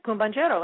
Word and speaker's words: El Cumbanchero: I'm going to play one that I'm going to try to pronounce right now, and I believe it's El Cumbanchero. – That's it, Cumbanchero.